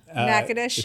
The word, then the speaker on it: Natchitoches: Natchitoches.